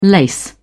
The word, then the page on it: lace: British English